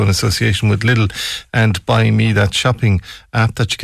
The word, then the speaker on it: Buymie: It's B U Y M I E, so it's Buymie is uh, what we're looking at there.